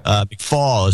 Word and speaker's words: McFaul: McFaul is it's pronounced, but McFaul.